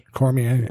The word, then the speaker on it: Cormier: He is called Cormier.